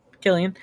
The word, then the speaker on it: Cillian: And Cillian Murphy, or Killian, I don't know how you pronounce his name. – Killian.